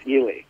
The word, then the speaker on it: Ely: Do you pronounce it Ely or Eli? – I can't tell you how much that means to me. That is exactly how I pronounce that. It's Ely.